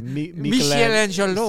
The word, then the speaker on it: Michelangelo: I believe the Statue of Limitations is at the Louvre, and it was a Michelangelo. – That's right. – And well, be careful how you say that Italian gentleman's name. You might not have pronounced it. – Michelangelo.